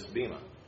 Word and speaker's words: Bema: That's called the Bema, or I understand in Greek it's called Bema. They pronounce the B like a V.